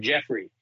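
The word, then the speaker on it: Geoffrey: I had a suitcase and a cape, and my stage name was Mr. Jeffrey. But it was spelled with a G, G-E-O-F-F-R-E-Y. Mr. Jeffrey with his Oxitrix.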